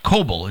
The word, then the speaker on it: Koble: Koble. It's just like I tell my friends this, and it's like Noble, only it's with a C.